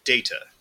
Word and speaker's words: Data: Well, there's only one correct way to say the name of these. Cyborg from Star Trek The Next Generation, and that is Data.